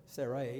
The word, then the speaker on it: Sarai: Sarai is the way it's still pronounced here. Her name hasn't been changed yet.